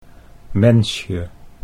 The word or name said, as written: Mensje